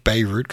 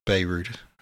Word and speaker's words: Bayreuth: I keep saying Bayreuth because I don't know how to pronounce this. It's Bayreuth. It's not Beirut. It's, I don't know, whatever that is.